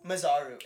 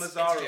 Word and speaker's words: Masaru: It's spelled Masaru, so like I just say Mazaru, but there's almost like a roll on the R that like, sounds like a Spanish thing.